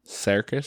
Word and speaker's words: Serkis: I would say Andy is is it Serkis is how you pronounce his last name. Serkis. Cir- circus, yeah.